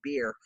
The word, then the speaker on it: Bier: Shout out to owner Greg Beer, and go figure, he owns a bar and his last name's Beer. – Yeah, I love it. – It's spelled B I E R, but it's still pronounced Beer.